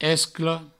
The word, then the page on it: escla: esclo; provençal